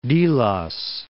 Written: Delos

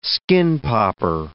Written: skin-popper

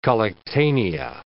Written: collectanea

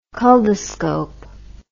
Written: culdoscope

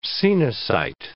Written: coenocyte

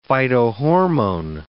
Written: phytohormone

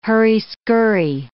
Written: hurry-scurry

